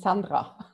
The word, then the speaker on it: Sandra: Am I pronouncing Sandra correctly or is it Sandra? Sandra. Sandra. – Well, it depends on the language, but in Austria, it's Sandra.